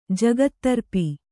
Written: ಜಗತ್ತರ್ಪಿ

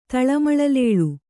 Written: ತಳಮಳಲೇಳು

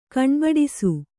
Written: ಕಾಣ್ಬಡಿಸು

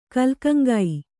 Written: ಕಲ್ಕಂಗೈ

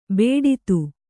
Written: ಬೇಡಿತು

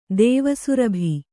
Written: ದೇವಸುರಭಿ

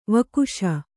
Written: ವಾಗೀಶ

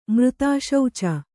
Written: ಮೃತಾಶೌಚ

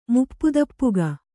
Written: ಮುಪ್ಪುದಪ್ಪುಗ

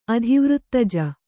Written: ಅಧಿವೃತ್ತಜ

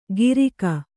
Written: ಗಿರಿಕ

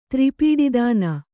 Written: ತ್ರಿಪೀಡಿದಾನ

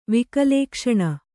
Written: ವಿಕಲೇಕ್ಷಣ

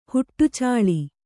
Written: ಹುಟ್ಟುಚಾಲಿ

